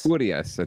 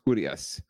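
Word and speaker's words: Urías: Urias. I think the accent is on the U. So it's Urias.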